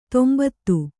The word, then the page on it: ತಂಬತ್ತಿ: tambatti